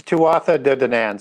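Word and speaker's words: Tuatha Dé Danann: The Tuatha De Danann.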